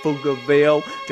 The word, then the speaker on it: Pflugerville: When you say Pflugerville, the P is silent.